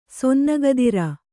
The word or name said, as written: ಸೊನ್ನಗದಿರ